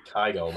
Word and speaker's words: Kygo: Before that was Raging by Kygo.